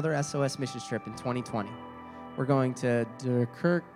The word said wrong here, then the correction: Dakar: Senegal. Dakar, Senegal. I'm bad at pronunciations.